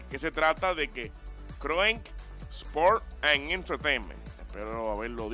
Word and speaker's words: Kroenke: Kroenke Sports and Entertainment.